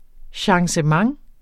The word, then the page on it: changement: ɕɑŋsəˈmɑŋ; ɕɑŋɕˈ-